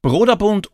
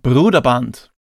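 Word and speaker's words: Brøderbund: Also ich bin mir ziemlich sicher, sagen wir es einmal so, nicht Broderbund oder Bröderbund, sondern Bruderband. Und das Spiel ist indiziert.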